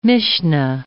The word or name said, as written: Mishna